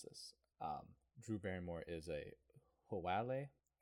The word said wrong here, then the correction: haole: which is Haole.